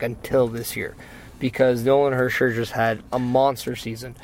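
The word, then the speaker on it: Hischier: Hersher.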